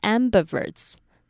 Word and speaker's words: ambiverts: 第一个单字是 ambiverts，它的短母音的那个 it 要轻，然后要短。